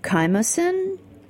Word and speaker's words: chymosin: I do like your it's a picture of rennet, the chymosin.